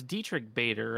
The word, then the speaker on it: Diedrich Bader: Um, I be, it's Diedrich Bader, right? – Is that mm-hmm. how you mm-hmm. say his name? Yep.